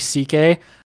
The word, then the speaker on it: Szekely: And so his last name is normally spelled S-Z-E-K-E-L-Y, but that's pronounced C-K in Polish.